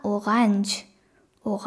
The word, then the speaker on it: Orange: これフランス語から来てるのでフランス語の発音なんですけど、これがオランジュ、オランジュっていう風に言います。